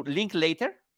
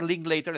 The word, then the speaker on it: Linklater: ¿Linklater? Sí. Richard Linklater, el, este extraordinario director, que...